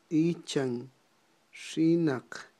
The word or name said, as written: ichanxinaq